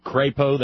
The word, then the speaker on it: Crapo: He pronounces it Crapo. That's a long A.